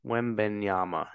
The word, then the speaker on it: Wembanyama: But um, you mentioned Wembenyana. Um, mm-hmm. did I say did I say his name right? Wem Wembanyama, yeah.